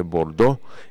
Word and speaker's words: Bordeaux: en francés se dice Bordeaux y se escribe Bordeaux, es la identificación que tiene esto.